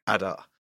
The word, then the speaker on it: Adar: Adar. – So the second Adar, if you add a year to that, where do you end up? Is it the same day in the first Adar? – Brigadoon.